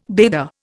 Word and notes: bather: Indian

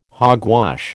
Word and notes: hogwash: American English

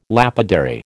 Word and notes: lapidary: American English

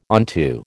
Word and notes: onto: American English

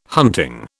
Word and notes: hunting: British English

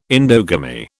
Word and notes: endogamy: British English

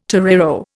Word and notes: torero: Indian

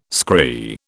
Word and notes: scry: British English

